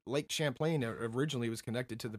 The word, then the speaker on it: Champlain: Well, before we go on to beluga whales, Lake Champlain, if that's how you say it.